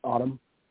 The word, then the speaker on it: Autumn: Is this, let's see, am I saying this right? Is it Otto? – Autumn. – Okay. A-U-T-U.